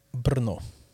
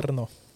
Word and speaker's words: Brno: Och de är ju i Tjeckien och drar igång U19 VM idag i Brno. – Ja. Brno. Mm. Ja. Det är säkert korrekt uttal. – Nej, ja, jag vet inte. B-R-N-O. De gillar ju sina konsonanter där nere.